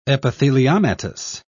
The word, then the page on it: epitheliomatous: British English